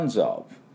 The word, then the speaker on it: tons of: だから tons of tons of という風になります。もうあです。はい、ちょっと喉の奥で出すあですね。